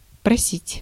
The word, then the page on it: просить: /prɐˈsʲitʲ/